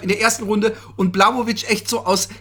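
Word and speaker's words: Błachowicz: Ich habe schon das Gefühl gehabt, dass Blachowicz, wie sie ihn in Amerika nennen, dabei heißt der hundertprozentig Blachowicz. – Er heißt Blachowicz und eigentlich nennen ihn auch alle so. – Nee, sagen alle Blachowicz, weil sie natürlich nicht aussprechen können in Amerika.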